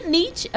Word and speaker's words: Nietzsche: É isso, é assim que pronuncia Nietzsche.